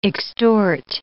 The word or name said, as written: extort